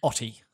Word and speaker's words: Hoty: Mama Murphy, played by Dee Hoti. Hoti? She's a real hottie? I don't know. H-O-T-Y, how would you say it? – Hoity. – Hoody. Dee Hoody. – The H is silent and it's pronounced Otty.